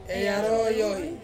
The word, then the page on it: arowoi: Ansus